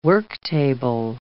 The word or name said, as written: worktable